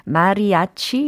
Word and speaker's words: mariachi: 특히 멕시코의 밴드로 mariachi 이렇게 발음을 해야지 정확하지 않을까 싶어요.